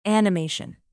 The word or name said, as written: animation